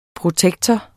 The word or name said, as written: protektor